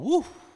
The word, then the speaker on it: Whew: It's actually called Woo. That's really how it's pronounced, but. – Okay. – W-H-E-W, Buckethead's.